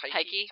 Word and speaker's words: Tyche: Is that how it's pronounced? – Tyche.